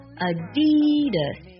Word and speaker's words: Adidas: 阿迪达斯的它的最标准的读音读作 Adidas Adidas，先低再高然后再轻 Adidas。